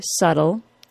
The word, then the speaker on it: subtle: In fact, pronouncing the B sound is wholly incorrect. Let's put aside the background of the word subtle and quickly talk about the other interesting aspect of its pronunciation. The letter T of the word subtle is not pronounced as a true T sound. It's pronounced as a quick D sound. Remember, this change happens to the T sound when it occurs after a vowel or R sound and before a vowel, R sound, or L sound.